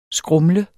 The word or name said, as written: skrumle